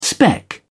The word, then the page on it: spec: British English